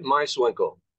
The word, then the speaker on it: Meiswinkle: That's David Meiswinkle.